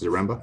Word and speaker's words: Zaremba: Did I say that right, Seth? – Yeah, close enough, Zaremba.